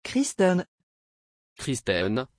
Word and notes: christen: French

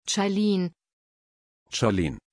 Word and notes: charlene: German

